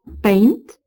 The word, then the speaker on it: pain: À l'époque des copistes de notre mort Arthur et de notre Perceval, ces mots se prononceraient respectivement chez eux, bank, paint, blanc, une, brune, fine, bon, fine.